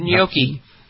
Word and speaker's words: gnocchi: Their cauliflower, what is it, cauliflower gnocchi? How do you pronounce it? Gnocchi? – Gnocchi.